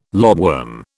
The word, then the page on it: lobworm: British English